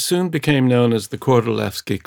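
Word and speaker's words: Kordylewski: It looks like Lewski, but it's Levski because the Poles pronounce a W like a V. Kordolewski.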